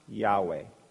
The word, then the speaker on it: YHWH: And it's spelled Y-H-W-H. You know what that stands for? Yahweh.